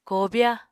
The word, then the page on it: kobya: Apatani